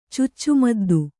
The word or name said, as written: ಚುಚ್ಚುಮದ್ದು